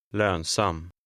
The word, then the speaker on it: lönsam: Number 10. The stress is on the second syllable. – Lönsam.